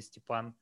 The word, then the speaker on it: Stepan: And some people try to you know to pronounce it correctly, Stepan.